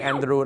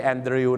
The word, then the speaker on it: Andrew: Andrew นะครับไม่ใช่ Andrew นะฮะ Andrew